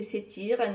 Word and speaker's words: Satir: et qui répond parfois au nom de Satire, prononcé Satire,